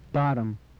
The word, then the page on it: bottom: English